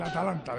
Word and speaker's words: Atalanta: El Atalanta de el Atalanta. Ahora lo he dicho bien, Atalanta, vaya hombre.